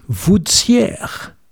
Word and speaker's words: Vouziers: Vouziers. Les gens du patelin prononcent ainsi « Voudsière ».